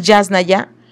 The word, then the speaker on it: Yasnaya: De hecho es Yasnaya, Yasnaya, perdón, tiene acento en la primera A, Yasnaya Elena Aguilar Gil, eh, es escritora, lingüista, traductora, investigadora y activista ayug, o sea, mije.